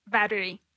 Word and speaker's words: battery: Some people will only say batteries. They'll miss the E in the middle. So say it honestly. How would you say it honestly without thinking about it? Or can you? – Battery. – Okay, so you do say it with an E.